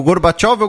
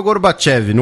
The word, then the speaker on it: Gorbachev: Gorbachev ou Gorbachev? No caso, as duas pronúncias são aceitas.